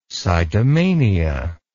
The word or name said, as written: sitomania